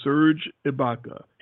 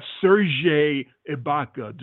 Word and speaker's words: Serge Ibaka: So this guy's name is Serge Ibaka, S-E-R-G-E, Serge Ibaka. His name is not Serge Ibaka, dude.